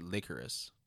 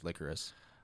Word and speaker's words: Lycoris: Is it Lycoris? Did you double check on that? – It's actually Lycoris. – Shut the fuck up. – Yeah, phonetically it says Lycoris.